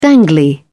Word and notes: dangly: British English

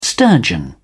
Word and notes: sturgeon: British English

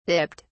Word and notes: dipped: British English

